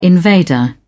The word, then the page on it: invader: British English